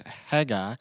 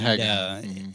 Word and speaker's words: Haggai: And there's great debate at times and confusion at other times about how to pronounce this minor prophet book. – I'm going to roll with Haggai. – And Haggai is the way that South Alabama boys go pronounce it, but there might be some other pronunciations out there.